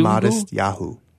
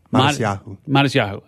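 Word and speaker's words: Matisyahu: Is it Matuyasu Nubu? – Modest Yahu. – Modest Yahu. Okay.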